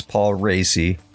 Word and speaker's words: Paul Raci: Yes, that is how you pronounce his name. It's Paul Racy.